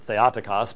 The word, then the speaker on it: Theotokos: Which I think I've talked to you about before, which I believe in modern Greek would be Theotokos, yes? Being the Erasmian pronunciation guy that I am, I would pronounce it Theotokos, but anyways.